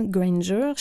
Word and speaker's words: Granger: Illustre Jonathan Granger, chercheur au laboratoire de psychologie cognitive à l'Université d'Aix-Marseille. J'ai prononcé son nom à l'anglaise parce qu'en fait, il n'est pas de langue maternelle, en française.